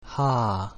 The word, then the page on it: H: Latin